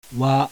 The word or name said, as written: WA